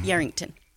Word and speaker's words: Yarrington: Yarrington.